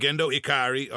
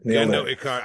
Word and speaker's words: Gendo Ikari: I'm gonna try Gendo Ikari. Did I say it right? – Ikari. – Gendo Ikari. All right, I'm trying. – Yeah, that's it. – Gendo Ikari.